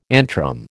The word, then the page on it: antrum: American English